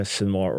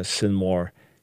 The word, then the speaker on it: Sinwar: his name is alternately pronounced Yahya Sinwar or Yahya Sinwar, is now dead in a random battle, accidental from the Israeli point of view.